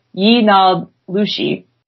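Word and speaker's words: yee naaldlooshii: In Navajo culture, a skinwalker is and the term for it is pronounced Yinalushi.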